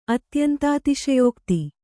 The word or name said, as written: ಅತ್ಯಂತಾತಿಶಯೋಕ್ತಿ